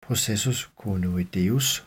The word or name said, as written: processus coronoideus